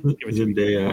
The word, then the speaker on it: Zendaya: Z- Zendania may be great. Uh, Sydney Sweeney, maybe isn't that her name? Zendania. Zendaya, I think it was Zendaya.